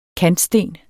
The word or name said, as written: KANTSTEN